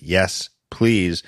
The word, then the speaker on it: Yes Plz: You spell it Y E S P L Z. That's the gimmick. Yes, please.